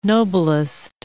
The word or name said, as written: noblest